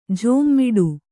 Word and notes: ಝೋಮ್ಮಿಡು: jhōmmiḍu

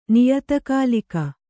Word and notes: ನಿಯತಕಾಲಿಕ: niyata kālika

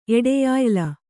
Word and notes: ಎಡೆಯಾಯ್ಲ: Kannada

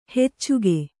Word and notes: ಹೆಚ್ಚಿಗೆ: kannada

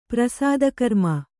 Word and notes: ಪ್ರಸೂತಿಕರ್ಮ: prasūti karma; Kannada

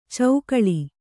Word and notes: ಚೌಕಳಿ: caukaḷi

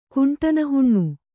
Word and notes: ಕುಂಟನಹುಣ್ಣು: kuṇṭana haṇṇu

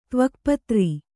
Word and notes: ತ್ವಕ್ಪತ್ರ: tvakpatra

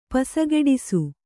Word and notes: ಪಸಗೆಡಿಸು: pasageḍisu

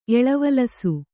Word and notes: ಎಳವಲಸು: kannada

